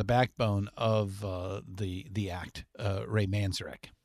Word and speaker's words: Manzarek: That's how you say his name, by the way. – Manzarek. – Manzarek, yeah. – Okay. – Yeah, or Manzarek, I guess some people, but I was always taught it was Manzarek.